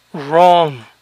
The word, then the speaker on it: wrong: Equivocado, wrong, wrong, la doble U inicial no se pronuncia, wrong, rap, rest, right.